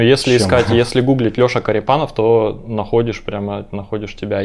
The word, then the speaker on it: Корепанов: Только Корепанов, наверное, потому что мне уже тут спрашивали, как ударение. Я так понимаю, что классическое – это Корепанов, но у меня родственники переехали на юг, и там как бы поменялось. Так что Корепанов.